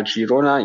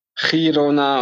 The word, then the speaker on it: Girona: Girona liegt in Katalonien und auf Katalanisch heißt es nun mal Girona. Ihr macht das also komplett richtig und es heißt nicht Girona und schon gar nicht Girona.